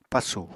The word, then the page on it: pasù: Apatani